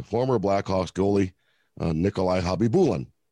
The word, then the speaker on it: Khabibulin: Right now, that's how you and I said his name, right? Right. Believe it or not, in one of the early, early pronunciation guides, they listed him as Habibulin.